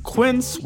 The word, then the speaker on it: Quince: Today Explained's support today comes from Quince, which rhymes with since, but is spelt with a Q-U.